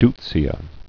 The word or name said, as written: deutzia